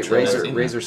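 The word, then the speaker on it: Razr: Was it Razer that makes that giant? It's like a Windows 7. – Tablet game thing. Oh, yeah, this giant. That sounds right. Razer sounds right.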